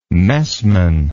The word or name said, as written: messman